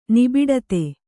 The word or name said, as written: ನಿಬಿಡತೆ